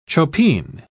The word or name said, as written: chopine